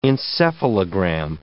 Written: encephalogram

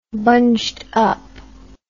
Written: bunged up

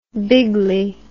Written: bigly

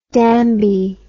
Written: Danby

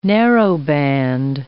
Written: narrowband

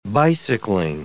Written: bicycling

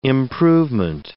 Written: improvement

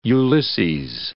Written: Ulysses

